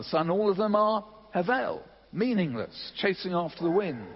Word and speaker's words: Hevel: The second one is Hebel. Well, actually, it's pronounced Hevel, H-E-V-E-L, Hevel.